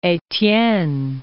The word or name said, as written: Estienne